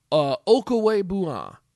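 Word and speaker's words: Okwuegbunam: Albert O, but Okwebuna is at least the pronunciation that we have, and a guy who, look, I'm very familiar with Drew Locke.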